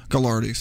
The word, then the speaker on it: Gagliardi: Gagliardi, sorry, that's how they pronounce it.